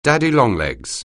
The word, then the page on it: daddy longlegs: anglais